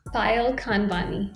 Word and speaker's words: Payal Khanwani: p-a-y-a-l dot K H A N W A N I. How do you say your last name, Payal? – Payal Khanwani. – Payal Khanwani.